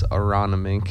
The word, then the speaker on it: Aronimink: But if you say it as it's spelled, it's Aronimink. – Aronimink.